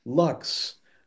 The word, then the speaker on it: luxe: I can't remember where exactly, where you're like, yeah, it's luxe, like deluxe, but it has the E just for class, you know. – Silent E.